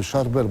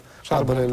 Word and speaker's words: Szarbel: I mamy już lekcję wymowy, Szarbel, bo to mówił Habral, Szarbel. Tak, tak. – I dlatego Szabrel też, jest dobra.